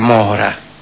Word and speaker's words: Mohra: pues allí se ubicaba la aldea de Mora. Se escribe M-O-H-R-A.